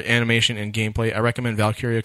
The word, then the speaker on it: Valkyria: Yeah, Valkyria.